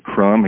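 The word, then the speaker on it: Benjamin Creme: Benjamin Crum. He spells his name Cream, but he pronounces it Crum, and I think that's a good way to pronounce it for him.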